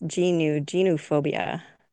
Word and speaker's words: geniophobia: Gynophobia? Gynophobia? No? – No, it's geniophobia, fear of knees.